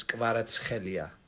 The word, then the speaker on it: Kvaratskhelia: The key is when there's a K and an H, you pronounce that as kh. I know that's very difficult. But then when there's T and an S next to each other, you pronounce that as ts. So it comes out to his first name is Hvica. – Hvica? – Hvica. – Hvica. – And the last name is Kvaratskhelia.